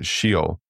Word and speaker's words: Sheol: The word translated as hell in the Old Testament is Strong's H7585, and this Hebrew word is pronounced as Sheol, and it appears a total of 65 times in the Old Testament.